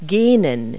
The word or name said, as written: yawn